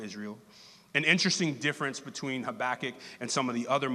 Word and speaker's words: Habakkuk: Often pronounced Habakkuk, as I pronounced it when I first ever saw it, is actually pronounced Habakkuk.